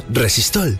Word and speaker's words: Resistol: El resistol se llama así por el vocablo inglés resist all, que se pronuncia resistol.